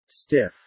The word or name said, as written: stiff